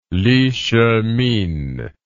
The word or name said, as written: Li Shihmin